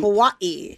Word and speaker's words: Kauai: To Kauai. – To Hawaii. – Kauai.